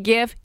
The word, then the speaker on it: GIF: And as his acceptance speech, the words, "It's pronounced GIF, not GIF," appeared on the screen. – Okay. – Apparently, he was emphatic about that. So if you've always wondered, is it GIF, is it GIF? It is GIF.